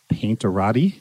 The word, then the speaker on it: Painterati: Brushmasters and Painterati? – Yes.